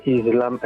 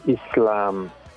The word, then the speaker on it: Islam: Volevo dire una cosa abbastanza importante, che la pronuncia della nostra religione che voi fate tutti, cioè Islam, è sbagliato, perché si dice Islam.